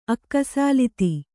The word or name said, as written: ಅಕ್ಕಸಾಲಿತಿ